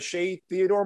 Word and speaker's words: Shea Theodore: You're going to have the Shea Theodore market, and there's plenty of collectors, and they're just not a lot.